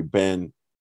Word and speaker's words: Banchero: the CH, it's like Cherry. And he said he asked, was a security guard at Chase. He said he asked Paolo that. And he said, yes, that is the correct pronunciation. So it's like Cherry, Banchero.